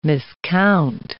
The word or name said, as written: miscount